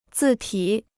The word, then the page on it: 字体: zì tǐ